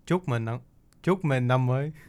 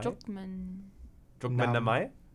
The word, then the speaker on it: Chúc mừng năm mới: I'm from the north, so it's heavier on the accent. We say chúc mừng năm mới.